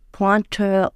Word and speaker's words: Pointeur: Also und äh, die, die Spieler heißen dann Leger und Schießer oder Pointeur und Tireur, französisch ausgesprochen.